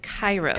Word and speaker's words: Kairos: So it's actually pronounced Kairos. – Kairos Care.